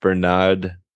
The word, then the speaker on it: Bernaud: I mean, this still has that, I think it's pronounced Bernaud, B-E-R-N-A-U-D, you know, ceramic magnet.